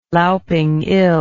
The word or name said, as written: louping ill